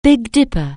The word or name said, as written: Big Dipper